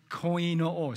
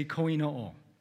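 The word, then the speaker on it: koinō (κοινόω): It means, here is the meaning in Greek, it's pronounced koinō, say koinō.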